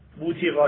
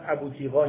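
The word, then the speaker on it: بوطیقا: بوتیقا. اینا ضبط های مختلفه، من دقیق ترین ضبطش رو آوردم، مثلا ابوتیقا اینا میگن ولی اون دقیق ترین ضبطش بوتیقاه. برید به انگلیسیش هم رجوع کنید میبینید همین تلفظ صحیح تر.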